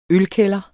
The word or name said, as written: ølkælder